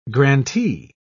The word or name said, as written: grantee